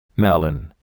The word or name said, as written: melon